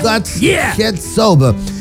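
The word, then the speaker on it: Goats Head Soup: God Head Soap de los Rolling Stones. ¿Lo dije bien, pelado? ¿Ah? – Yeah. – God Head Soap.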